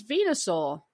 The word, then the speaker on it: Venusaur: And this is Venusaur.